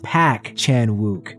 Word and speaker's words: Park Chan-wook: The R in surname Park is silent. Thus, the Park in Park Chan-wook is pronounced Pak Chan-wook.